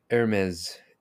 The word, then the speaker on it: Hermès: The Hermès H08. – I'm glad you've pronounced it properly this time.